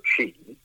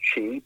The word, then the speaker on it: Twi: Yes, English is the official language, but I'll say the unofficial language is actually called Chi. It's pronounced Chi, but it's actually spelt T-W-I.